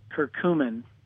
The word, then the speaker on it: curcumin: I take turmeric and coumarin, is it? Am I saying that correctly? I know they're both together. Coumarin, I think it is. Yep. – Well, it's turmeric and curcumin. – Curcumin, yep.